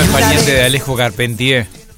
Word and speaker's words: Charpentier: Santiago Moreno Carpentier, o Charpentier, creo que es Carpentier en realidad la pronunciación de ese apellido,